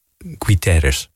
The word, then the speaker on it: Guterres: Guterres of zoiets. Zo spreek je dat uit. Guterres.